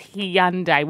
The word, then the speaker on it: Hyundai: It's pronounced Hyundai. – No, it's Hyundai. – Oh, shivers. It is Hyundai.